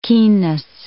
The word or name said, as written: keenness